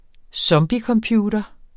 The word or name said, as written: zombiecomputer